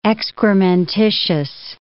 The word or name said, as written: excrementitious